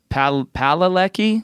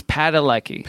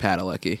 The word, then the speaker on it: Padalecki: Because someone else pronounced it Padalecki. – Well, they're wrong. – It's Padalecki. – Padalecki.